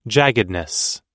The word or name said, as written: jaggedness